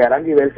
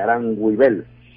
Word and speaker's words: Aranguibel: Entonces, me corresponde decirles que Aranguibel se escribe así como suena, Aran, A-R-A-N, pero después viene G-U-I, de alta L, Aranguibel. Entonces ahí ustedes también entran en contacto con nosotros.